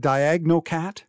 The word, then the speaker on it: DiagnoCAT: DiagnoCAT? – Yes.